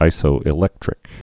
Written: isoelectric

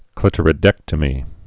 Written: clitoridectomy

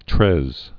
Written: Louis Treize

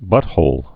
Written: butthole

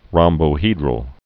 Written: rhombohedral

